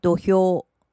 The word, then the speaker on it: 土俵: 土表念成土俵，土俵，土俵。我们来复习一下相扑，相扑，相扑，相扑。大相扑，大相扑。大相扑，大相扑，力士，力士，力士，力士，横纲，横纲，横纲，横纲，大关，大関，大関，大関，土表，土俵，土俵，土俵。